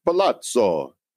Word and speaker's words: Palazzo: Is that how you pronounce it? Palazzo.